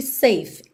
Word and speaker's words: safe: Também juntando porque safe, embora termine em vogal E, o E não é pronunciado. Então, o som é como se ela terminasse numa consoante, F.